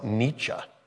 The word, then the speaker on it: Nietzsche: Now, it's spelled Nietzsche, but I learned it's pronounced Nietzsche.